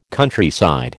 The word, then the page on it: Countryside: American English